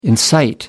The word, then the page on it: incite: American English